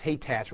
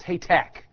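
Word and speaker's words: Hatach: And you think it's Haytach. Well, it's Haytack. It sounds like a K on the end.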